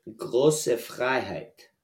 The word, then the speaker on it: Große Freiheit: It's pronounced Große Freiheit.